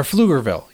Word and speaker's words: Pflugerville: Or Pflugerville. – Yeah. – It's with the silent P.